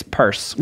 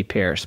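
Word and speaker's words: Peirce: And everyone mispronounces it because it looks like Pierce and it should be Pierce, but it's Purse.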